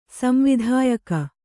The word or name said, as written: ಸಂವಿಧಾಯಕಿ